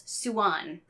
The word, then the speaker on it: Suan: It looks like it's derived from maybe some Eastern Asian or Southeastern Asian language, in which case it would be two syllables, Suan. – Yeah. Yeah. That is and that's the way she pronounces it herself. Suan.